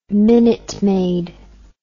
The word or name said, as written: minute maid